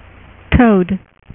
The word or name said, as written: Toad